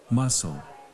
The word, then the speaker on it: muscle: Muscle. – One more time. – Muscle. – Your turn. 3, 2, 1. Muscle. นักเรียนปลายทางด้วยนะครับ Together, everybody. Muscle. You stress on the first or second syllable. First or second syllable. First. Muscle. Muscle.